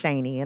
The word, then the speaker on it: Shaney: My name is Shaney, and I do spell that S-H-A-N-E-Y.